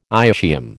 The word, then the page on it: ischium: American English